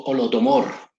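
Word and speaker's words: Holodomor: Yo originalmente se llama Olomodo, Olodomor, Olodomor, pero yo lo quise colocar Olomodor para, la facilitación de la pronunciación en la canción.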